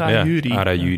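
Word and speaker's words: Arajuuri: Arajuri. – Ik vond de eerste uitspraak heel goed eigenlijk. – Arajuri.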